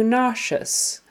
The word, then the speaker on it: nauseous: And it's, again, the accent is on the first syllable. Nauseous. So nausea, nauseous. Sh, sh. And the middle sound of nauseous is that sh, sh sound, even though it is spelled with just an s-e-o-u-s.